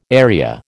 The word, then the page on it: area: American English